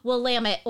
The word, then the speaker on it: Willamette: There's a town that doesn't look like, it looks like it's spelled Willamette, but it's Willamette. Willamette. – It's Willamette, damn it.